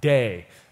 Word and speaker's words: dei: It's the Greek word, if you care, it's pronounced dei, as in D-E-I, dei.